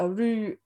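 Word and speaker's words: athrú: I am Aru.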